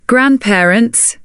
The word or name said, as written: grandparents